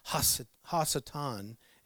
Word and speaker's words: Hasatan: And so it's Hasatan is actually how it's said. Hasatan is when you're talking about this heavenly accuser called the devil.